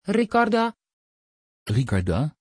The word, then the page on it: Ricarda: Dutch